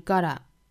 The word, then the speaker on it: gotta: gotta 这个词美音的话呢是 gotta gotta 注意中间的这个 t 浊化成 d 的发音 gotta 后面 decide 双元音 i decide For yourself, yourself, 后面的 self 有一个舌侧音，我们注意舌尖底上齿龈的发法哈。